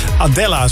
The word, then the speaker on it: Adela: ik je voorstellen aan Adela. – Hey Adela. – Nee, niet die Adela van de Jan Smit fangroep. Nee, dit is een Adela. Adela, zo spreek je het eigenlijk uit.